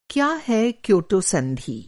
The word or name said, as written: क्योटो